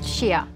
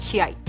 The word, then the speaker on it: Shia: The branch of Islam is pronounced Shia, not Shiite.